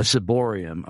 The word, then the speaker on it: ciborium: Siriboam, sorry if I'm mispronouncing that. It's been years since I've been a Roman Catholic. But that is why they do that. A ciborium, I'm sorry, I mispronounced it. Ciborium.